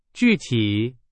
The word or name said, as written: 具体